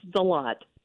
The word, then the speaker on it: Deslatte: You were close. It's Deslatte.